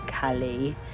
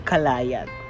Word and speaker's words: Caillat: And before we officially jump in for today's episode, I just want to mention Rachel and Atperna for letting me know how to correctly pronounce the last name of Colbie Caillat. Because last time, what I said was Kalayat.